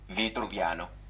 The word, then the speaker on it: Vitruviano: Inglise keeles ta on siis Vitruvian eh,, itaalia keeles ma ei, ma ei uurinud välja kohe. aga pane kohe translate to italian. Vitruviano, Vitruviano. pane mees ka Uomo Vitruviano, Uomo Vitruviano.